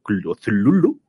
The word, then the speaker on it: Cthulhu: los cuentos de los mitos de Cthulhu, o Cthulhu, como él decía que se pronuncia,